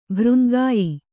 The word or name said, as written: ಭರಂಗೆಯ್